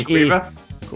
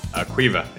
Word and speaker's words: Caoimhe: Kaoimhe. I don't know how you say it. Caoimhe. – You mean Quiva? – Was that, how do you say it? – Quiva. – Quiva.